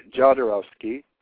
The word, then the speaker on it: Jodorowsky: In Spanish, it's Jodorowsky, but Jodorowsky.